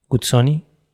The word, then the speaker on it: Guzzoni: José Guillermo Guzzoni Cox.